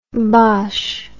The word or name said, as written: bosche